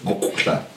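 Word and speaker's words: muqw'lha: It's QW apostrophe. Mukla. See, everyone, we grew up saying it wrong.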